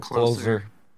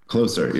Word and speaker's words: Closer: Closer. – Closer, yeah. – Closer. – Is it closer or closer? – Closer. Okay. C-L-O-S-E-R. – They're spelled the same way, though.